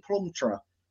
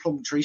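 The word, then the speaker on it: Plumptre: We are obviously talking about the Nigerian Ashley Plumtree. Now, people might say she's called Plumtree. She's not. It's Plumtree. That's one name I did learn was not pronounced the way I thought it was.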